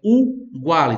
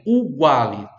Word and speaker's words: uguale: lados uguali, também o i ali para colocar no plural, uguali, atenção, é uguali, tá? A primeira letra é a vogal U. Eu vejo pessoas falando errado essa palavrinha, é muito simples, é só ter atenção. É uguale, tá bom? Uguale. Começa com U.